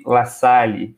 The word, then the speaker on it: La Salle: La Salle. Falei certo? – Correcto, sí. Correcto, sí.